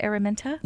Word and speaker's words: Araminta: Araminta? – Yes.